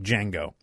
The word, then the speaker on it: Django: That's the premise, the Saturday Night Live sketch that ran in 2013 titled Jesus Uncrossed with a silent D, like Django. But they put the silent D in front of it.